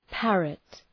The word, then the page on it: parrot: {‘pærət}